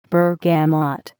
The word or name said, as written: bergamot